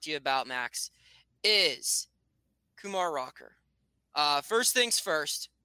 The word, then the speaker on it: Kumar Rocker: So the way I say his name is Kumar Rocker with the accent on the second syllable.